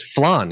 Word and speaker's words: flan: No, it's flan. – Yeah. I know it's flan. He messed me up. It's flan. – I wanted Casey to be like, yeah, give me a plate of flan.